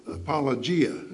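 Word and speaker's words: apologia (ἀπολογία): The Greek word there is I always mispronounce this word, apologio, or apologia. Apologia is better pronounced. Apologia. And it probably sounds very much like apology.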